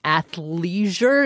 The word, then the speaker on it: athleisure: Athleisure, am I saying that right? – Yes.